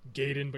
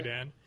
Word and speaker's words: Gaiden: Sorry, Gaiden, but you're supposed to pronounce it Gaiden.